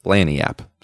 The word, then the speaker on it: Lagniappe: Pronounced Laniap. So I was wrong. – We're so wrong. It's Creole for bonus.